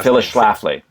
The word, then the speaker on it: Phyllis Schlafly: And that, Felix Schlafly was your example, right? – Say again? – Felix Schlafly, I don't know how to pronounce that. – Right, Phyllis Schlafly.